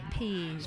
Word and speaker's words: pease: Peas as in P-E-A-S-E. – It is a yellow colour. – Peas. Like lease.